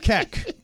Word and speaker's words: Keck: Keck. – K-E-C-K? – Yes.